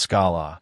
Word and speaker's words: Scala: In 2004, a Swiss professor named Martin Oderski released a new language called Scala, which I will pronounce Scala, and which compiles down to the same bytecode the JVM understands.